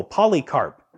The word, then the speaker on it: Polycarp: You can actually Google Polycarp, which is spelled like poly, many, and carp like the fish.